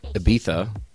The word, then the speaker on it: Ibiza: but recently you had a nice lengthy stay over in, I'm going to pronounce this right, Ibiza.